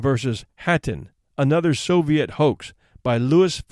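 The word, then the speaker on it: Khatyn: Hattin, in which the KH is pronounced like the English H, is a small village some 30 miles to the northeast of Minsk, the capital of Belarusia.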